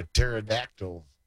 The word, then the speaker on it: pterodactyl: a pterodactyl go to the bathroom, because the P is silent.